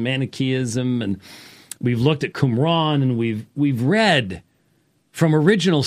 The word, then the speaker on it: Qumran: We've looked at portions from the Dead Sea Scrolls from Qumran, which is how it's pronounced, Qumran, yes.